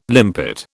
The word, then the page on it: limpet: British English